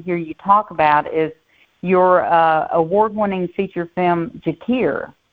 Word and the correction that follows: Jasir: Is it Jakir? – It's Jasir. – Jasir. Okay. – Yeah, Jasir. – I thought I was pronouncing that wrong.